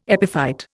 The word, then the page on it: epiphyte: Indian